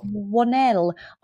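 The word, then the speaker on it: Whannell: How do you say his last name? Is it Wannell? – Wannell.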